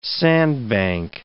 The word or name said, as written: sandbank